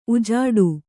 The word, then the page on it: ಉಜಾಡು: ujāḍu